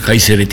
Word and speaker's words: Caesar: Caesar diye yazılıyor biliyorsunuz, yani Latince Kayseri diye okunuyor.